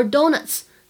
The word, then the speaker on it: donuts: Dollar 后面加 s 的话呢，发的是 z 的音，而 donuts 在末尾加上 s 会形成 ts 的发音。 Dollars to donuts，注意区别。